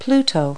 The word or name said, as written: pluto